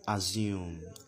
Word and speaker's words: assume: Assume is not pronounced assume. Okay, it's not z, it's assume. S, as you have in sin, as you have in sick. S, it is assume and not assume.